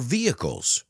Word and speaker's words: vehicles: We also use it to power our vehicles. Let's pronounce that word together. The H is silent. Vehicles.